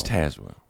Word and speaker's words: Tazewell: It's Tazewell. – Yeah. – Even though phonetically it should be spelled, it should be pronounced Tazewell with the E. A-Z-E would be A's.